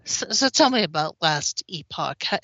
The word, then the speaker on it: Epoch: You're going to say, but it's pronounced Epic. And you're right. But that's not how they pronounce it in the game. The device is called the Epoch.